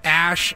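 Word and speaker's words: Æ: Remember we talked about Elon Musk and his girlfriend Grimes, who named their child X, and then it was like A-E, but it's pronounced Ash, and then A-12, so X-A-12.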